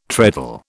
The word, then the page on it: treadle: British English